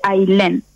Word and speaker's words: Ailén: No, mi nombre es uno de los pocos que conozco que se escriben A-I latina doble L-E-N. Se escribe Ailén y se dice Ailén.